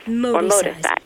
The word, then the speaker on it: Moticise: Now, this idea, how do you say it, Moticise? – Yeah, it's actually Moti from motivation and size from exercise. So it's fusing the two words. It's motivational exercise. – Moticise.